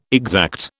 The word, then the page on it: exact: British English